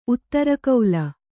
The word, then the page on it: ಉತ್ತರಕಾಲ: uttarakāla